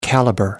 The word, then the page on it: caliber: British English